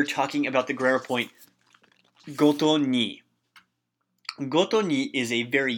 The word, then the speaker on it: 毎に: However, instead of being pronounced mai, it's pronounced goto ni.